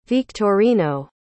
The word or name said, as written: victorino